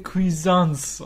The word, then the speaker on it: Cuisance: Ich glaube aber, es wird Michael Cuisance ausgesprochen. Wahrscheinlich war das auch Cuisance, genau.